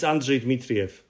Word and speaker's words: Andrzej Dmitriev: So in Polish, it's Andrzej Dmitriev, but it's impossible to pronounce even for Polish people.